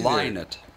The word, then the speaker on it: Lynott: Lynott. – Lynott? – Yes.